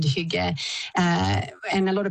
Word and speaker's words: hygge: It's pronounced hygge, yes.